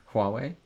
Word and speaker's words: Huawei: Huawei? – Huawei, yeah.